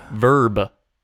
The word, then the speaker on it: Verb: Wait, is it Verb or Verve? – Verb. – Like spelled like a verb, like an action thing? – Like, yeah, verb. The V-E-R-B, Verb.